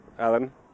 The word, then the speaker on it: Alen: M-A-X. – Hi, my name is Joanne. What's your name? – Alan. – Alan, how do you spell that? – A-L-E-N.